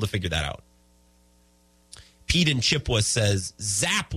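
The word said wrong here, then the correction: Zappe: was the freaking QB. Zappy. Sorry, I said it wrong. Zappy.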